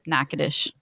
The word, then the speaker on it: Natchitoches: Also, if you're from Louisiana, you are very familiar with that because my dad used to brag about Natchitoches, and it does not spell that way at all. – Natchitoches? – So it's pretty common. Natchitoches.